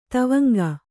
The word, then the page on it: ದೇವಾಂಗ: dēvāŋga